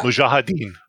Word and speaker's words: Mujahideen: Mujahideen? Did I pronounce that right? – Yeah. Mujahideen.